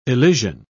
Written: elision

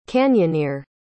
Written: canyoneer

